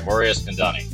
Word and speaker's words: Mori Eskandani: One of the most well-known poker producers and president of PokerGO, uh, Mori Did I pronounce that right, Mori Eskandani? – Yes, you did.